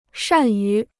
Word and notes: 善于: shàn yú

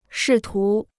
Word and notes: 试图: shì tú

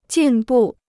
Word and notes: 进步: jìn bù

